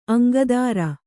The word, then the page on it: ಅಂಕದೆರೆ: aŋkadeṛe